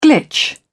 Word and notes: glitch: British English

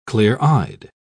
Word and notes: clear-eyed: British English

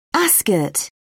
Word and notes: ascot: British English

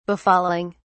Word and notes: befalling: British English

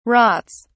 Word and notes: rots: British English

